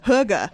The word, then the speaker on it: hygge: When Dylan and I visited Denmark, they have a saying, I think it's pronounced Hygge. – Hygge, where it's like coziness, feeling warm, like curling up under a blanket with the hot cocoa.